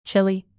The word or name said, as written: chilly